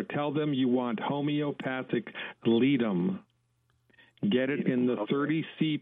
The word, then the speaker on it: Ledum: Okay, so the name of the medicine is Ledum.